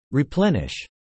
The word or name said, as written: replenish